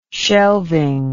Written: shelving

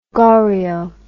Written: Goriot

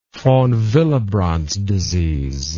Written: von Willebrand's disease

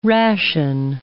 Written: ration

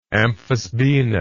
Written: amphisbaenous

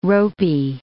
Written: ropey

